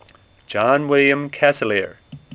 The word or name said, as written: Casilear